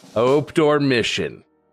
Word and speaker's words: obdormition: And I'm trying to pronounce it as phonetically as I can. – Obdormition. – Obdormition. – Obdormition. O-B-D-O-R-M-I-D-I-O-N. Obdormition. – That is correct. That is absolutely correct.